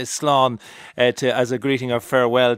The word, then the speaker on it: Slán: Slán is an easy in Irish. It's just a single syllable. – Slán.